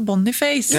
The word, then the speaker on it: Boniface: Han är från Nigeria, där uttalas det Boniface.